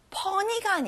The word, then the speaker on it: funny: 앞에 fa에 강세를 넣어보시고요. funny가 아니라 funny.